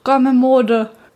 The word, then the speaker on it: Gommemode: Also, es heißt Gomme Mode. Gommel-Mode, also so wird es geschrieben. G-O-M-M-E-M-O-D-E.